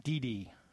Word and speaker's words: Didi: Didi is her first name. Rhymes with BB.